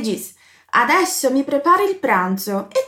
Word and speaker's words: pranzo: Como Alê estava preparando, estava fazendo seu almoço, ele diz: Mi preparo il pranzo. Preste atenção na pronúncia da letra Z. É como se a gente quase falasse um DZ, na verdade. Pranzo, pranzo.